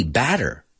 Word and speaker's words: batter: But in American English, that word, we pronounce those T's like a D, batter, batter, which sounds like masmalote, okay?